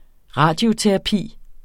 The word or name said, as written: radioterapi